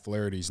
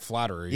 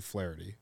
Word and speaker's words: Flaherty: On TikTok last week, I had a slew of Cardinal fans yell at me because I said Jack Flaherty's name wrong. I said you say it wrong all the time. – I said it Jack Flattery. – Yeah, you call him Flattery.